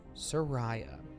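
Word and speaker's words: Saraya: She said, 'My name is pronounced Soraya.' She went ahead and spelled it out phonetically as well for fans.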